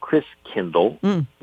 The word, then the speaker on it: Christkindl: And the German pronunciation of Christ child is Christkindl, which over the centuries got pronounced Kris Kringle.